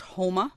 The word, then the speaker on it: Houma: It's a town called Houma, and it's spelled H-O-U-M-A. – Uh-huh. – Houma. – I'll keep that in mind. – It's not Homer with a Massachusetts accent.